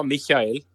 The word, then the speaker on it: Michael: Ditt förnamn uttalas inte Michael utan Michael. – Ja, Michael på tyska.